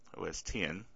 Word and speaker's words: OS X: Microsoft plans to discontinue support for its Internet Explorer browser on the Mac OS X platform, OS 10, for those of you who pronounce it that way, at the end of the year.